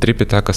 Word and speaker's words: Трипитака: Основной текст называется Типитака или Трипитака, э, в зависимости от того, на каком языке читается. Типитака – палийский язык, язык пали, Трипитака – санскрит.